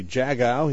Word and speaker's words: Jagow: I like to say if you're bird watching, you say, hey, look at that blue jay go. – Ah. – So it's Jago.